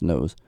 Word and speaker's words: Knouse: K-N-O-U-S-E. Knows. I think it's pronounced Knows.